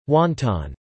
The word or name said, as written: wonton